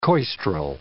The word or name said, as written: coistrel